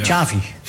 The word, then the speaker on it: Xavi: Hij zei zelf Xavi als chocolate. Hij zei het zelf. – Xavi. – Xavi dan. Hij zei Xavi like chocolate.